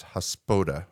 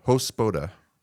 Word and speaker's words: Hospoda: It's a Hospada, is how you say it? – Hospoda? – I said it completely wrong. It's the opposite. It's Hospoda. Hospoda.